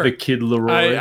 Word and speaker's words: The Kid Laroi: The Kid Laroi. Does that sound right? – Sure. – The Kid Laroi.